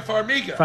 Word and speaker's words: Farmiga: F-A-R-M-I-G-A. – Who? – Farmiga. – Oh. – Vera Farmiga.